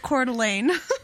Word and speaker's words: Coeur d'Alene: And so, for everybody who doesn't know, it's pronounced Coeur d'Alene.